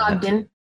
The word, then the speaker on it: Ogden: Yeah, your surname, I think, I was trying to pronounce that. I might have butchered that. – Ogden. It's an Ogden. English derived.